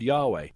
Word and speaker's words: Yahweh: Today, many Christians use the word Yahweh, the more original pronunciation, not hesitating to name the divine name since Jesus taught believers to speak in a familiar way to God.